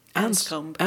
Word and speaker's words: Anscombe: Du har ju översatt en, en um, artikel här också om hur uttalar man hennes efternamn? – Anscombe. – Anscombe, tror mm. jag.